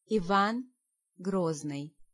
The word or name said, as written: Ivan